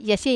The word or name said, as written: asiei